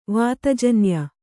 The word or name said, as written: ವಾತಜನ್ಯ